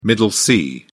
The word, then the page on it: middle c: British English